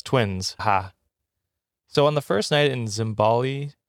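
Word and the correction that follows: Zambales: am I saying that right? – Zimbales. – Zimbales.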